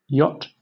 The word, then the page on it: yacht: British English